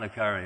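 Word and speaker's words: le Carré: There's this one novelist, I don't know how you pronounce it, is it Le Carre? He writes all these books about spies. Right? John Le Carre, that's right. I was never very good at French.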